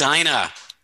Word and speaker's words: Edina: Am I saying that right? – But Edina.